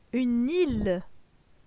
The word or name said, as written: Une ile